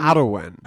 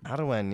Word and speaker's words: Arwen: There's been a storm ravaging the UK called Storm Arwen. I believe it's Welsh. – And Arwen. – Arwen, yeah.